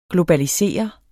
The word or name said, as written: globalisere